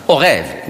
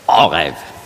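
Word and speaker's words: Orev (עורב): Heute spricht man das nicht mehr aus. Sagt man Orev in Israel. Althebräisch, Orev.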